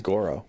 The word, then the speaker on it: Goro: Now, is it Goro or Goru? I just want to make sure I'm pronouncing it correctly. – I think it's Goro.